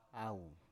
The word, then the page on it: au: Biak